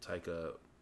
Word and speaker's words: Taika: This was a team effort, by the way, from Jermaine Clement of Flight of Conchords fame and Taika Waititi. Right? Takia? Is that how you say that? No, Taika. Taika. Yeah, I said it right the first time.